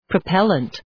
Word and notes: propellent: prə’pelənt

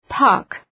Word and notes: puck: {pʌk}

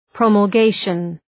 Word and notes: promulgation: ,prɒməl’geıʃən

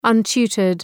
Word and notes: untutored: ʌn’tu:tərd

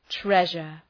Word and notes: treasure: ‘treʒər

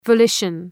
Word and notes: volition: vəʋ'lıʃən

